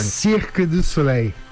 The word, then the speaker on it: Cirque du Soleil: qual que é a pronúncia correta em francês, você que esteve lá durante muito tempo? Fala pertinho do microfone. – Cirque du Soleil.